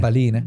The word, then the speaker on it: Ballina: Balina. Balina. Es con L, ¿no? – pero es, no, a ver, pero irlandés sería. Eh, balina.